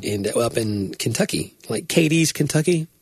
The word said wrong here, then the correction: Cadiz: Is that how you pronounce that? Katy's. – C-A-D-I-Z. – Cadiz.